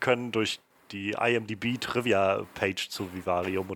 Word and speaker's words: Vivarium: Also wenn du es Englisch aussprechen willst, dann äh, Vivarium. Aber wenn du es auf Deutsch lassen willst, und ich meine, das, das Wort gibt es auch auf Deutsch, ne? Vivarium.